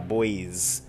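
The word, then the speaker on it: Bois: And the Glitter Boys, that's Glitter, and then Boys is spelled B-O-I-S. B-O-I-S. Yeah, Boys.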